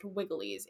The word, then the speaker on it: Vigiles: By the first century CE, the Praetorian Guard, the urban cohorts, and the, I will pronounce it Vigiles because that's how it's spelled, but it's pronounced Wigiles in Latin, were added to the list of those receiving grain doles.